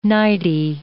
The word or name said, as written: nightie